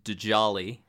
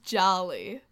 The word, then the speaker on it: Djali: I think it's just pronounced Jolly. – It is just Jolly, but I have to say it that way just for the first time to point out that it is a D-J-A-L-I. – To our dumb American brains, it's Djali, but we're just going to say Jolly because that's easier to pronounce and it's also correct. Jolly, who is able to kind of like count and kind of like the tricks that people will like have their dogs do.